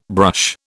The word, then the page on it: brush: British English